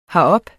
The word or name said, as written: herop